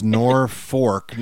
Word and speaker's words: Norfolk: Absolutely, but it's Norfolk, Nebraska, even though it's spelled with an L.